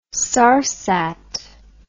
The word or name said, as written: sarsat